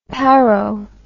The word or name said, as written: paro